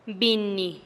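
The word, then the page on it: binnih: Teotitlán del Valle Zapotec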